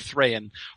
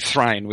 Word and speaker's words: Thrain: Especially, I find Thrain harder uh, to be consistent about, and I sometimes slip into Thrine, which is how I used to pronounce it when I was little.